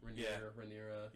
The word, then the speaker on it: Rhaenyra: Princess Rhaenyra. I think that's how you say her name. – Rhaenyra.